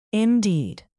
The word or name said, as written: indeed